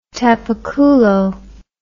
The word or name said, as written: tapaculo